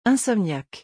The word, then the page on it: insomniaque: French